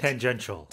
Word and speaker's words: tangential: What is that word? Tangent? How do you say it? – Tangential. – Tangential. – Yes.